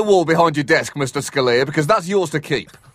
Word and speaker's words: Scalia: Is that the right pronunciation? – Oh, Scalia. It's Scalia, Scalia is technically his name.